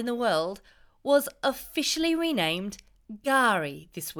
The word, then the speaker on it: K'gari: It's spelt K-G-A-R-I, but you'll hear it being said with a silent K, Gari.